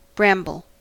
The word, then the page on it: bramble: American English